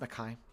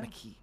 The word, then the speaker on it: Mekhi: Oh, that's how you pronounce it, Mekhi? I always said it Mekhi. But Mekhi sounds better.